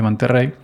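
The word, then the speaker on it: Monterey: Bueno, Ventura, yo estoy en Macos eh, Monterrey, que aunque tiene una R, se pronuncia como si tuviera dos, como el Opel, Opel Monterrey, que es Opel Monterrey, pero se pronuncia Monterrey.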